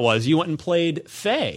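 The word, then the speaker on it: Fe: Yeah, it's pronounced Faye, but I just keep calling it Faye because it's spelled F-E.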